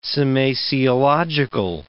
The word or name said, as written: semasiological